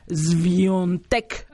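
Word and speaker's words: Świątek: Es que yo, es Iga Swiatek, pero me dice, se pronuncia Swiatek, así como con una S medio larga y la letra A es un sonido un. Swiatek, así es como se pronuncia.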